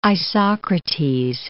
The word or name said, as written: Isocrates